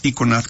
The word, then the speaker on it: Iconad: Iconad. – Con C. – Nuestro nombre es Iconad, pero es Iconad, como se escribe, como tú lo dices perfectamente bien.